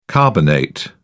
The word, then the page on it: carbonate: British English